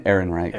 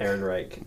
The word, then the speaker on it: Ehrenreich: Alden Ehrenreich. – Ehrenreich.